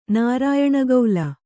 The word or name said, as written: ನಾರಾಯಣಗೌಲ